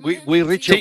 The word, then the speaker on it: Guy Ritchie: Lo he dicho bien. Guy Ritchie. – Sí, Guy Ritchie.